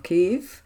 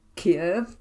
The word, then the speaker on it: Kyiv: Beyond drawing attention to the Tories' Scotland trouble, the new leader's first speech also earned pelters for her pronunciation of Kyiv, the Ukrainian name for their capital as Kiev, the much-hated and now abandoned Russian name.